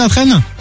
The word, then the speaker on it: Latresne: J'adore, c'est où la traîne? – C'est à côté de Bordeaux. – Ah bah génial, tu connais pas Justine Latraîne? – Mais ça s'écrit comment? – Comme la traîne. – L-A-T-R-E-S-N-E.